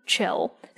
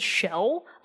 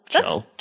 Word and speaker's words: Chell: Chell. Some people have said Shell. Uh, her name is spelled C H E L L. Chell. Chell. Yeah. What did you say? Shell? No, Chell.